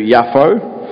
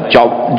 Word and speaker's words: Yafo: He went to, it's actually pronounced in the Hebrew Yafo, but it's probably Joppa or something like that, Joppa, and found a ship going to Tarshish.